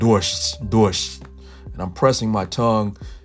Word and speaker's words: дождь: And just to notice a note on pronunciation, the word for rain is дождь, дождь. Now in Russian you have the D sound and then you have a soft the sound, which is called a мягкий знак.